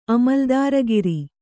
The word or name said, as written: ಅಮಲ್ದಾರಗಿರಿ